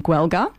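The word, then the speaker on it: Gaeilge: So you term the Irish language the Gaelic language, or in Irish, Gwelga. But then you also have Gaelic. So it's pronounced slightly differently, and that's the Gaelic language of Scotland.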